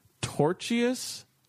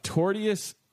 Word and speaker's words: tortious: When a woman ordered lasagna at a restaurant in the suburbs, she didn't expect her meal to come with a side of tortuous conduct. Am I saying it wrong? Torturous? There's no other R. T-O-R-T-I-O-U-S.